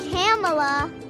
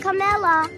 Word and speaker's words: Kamala: Sie heißt Kamala. Kamala. Okay. Das erste A ist ein O und die Betonung ist auf der ersten Silbe.